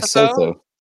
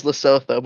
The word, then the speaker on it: Lesotho: Lesotho. – No, everyone just says Lesotho, but there's occasional annoying Americans that say Lesotho, and it's really annoying.